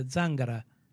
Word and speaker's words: Zangara: Giuseppe Zangara. – Zangara. – Which, when I talked to an Italian, said, well, we pronounce it the Zangara. Zangara. – Oh, an emphasis on the first.